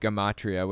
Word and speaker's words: gematria: It's pronounced gematria, which we'll get to in a minute.